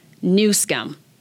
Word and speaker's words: Newsom: And just when I thought I was done with Gavin Newsom, and just for the record, the name is pronounced Newsom.